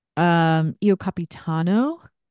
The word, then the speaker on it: Io Capitano: Io Capitano. I'm sure I said that wrong. Italy. – You said it right.